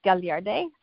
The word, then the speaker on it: Gagliardi: and my name is Zay Gagliardi.